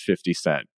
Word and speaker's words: 50 Cent: White people should always clearly pronounce 50 Cent. He's not fitty for you.